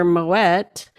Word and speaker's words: Moët: But even though it is a French company, it is pronounced Moët et Chandon because the original owner, and because I looked it up afterwards, because I'm like, she can't be right, but she was totally right. Because they had a pronunciation thing, and it's because the owner, Mr. Moët, was actually originally Dutch origin, and that's how they pronounced his last name.